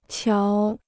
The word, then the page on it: 瞧: qiáo